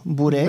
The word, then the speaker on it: Bure: Cameron Burr. – Candice Cameron Bure. – Bure Nisam znao da se izgovara bure, bure.